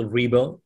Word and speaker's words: Ribo: Both Angel Ribo, my last name is spelled R-I-B as in boy O.